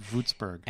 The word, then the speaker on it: Würzburg: Here's what I'm thinking. W is going to be pronounced like a V. – Yes. – So V. – Yeah. – In case you were wondering. – Würzburg. – And the Z is going to be more like a TS in English.